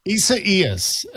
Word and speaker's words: Isaias: Isaias. And I was just talking Isiasis, to Scott about this. Isaias. I don't think I'm putting the pronunciation in quite right when I say it, but that's how you say it.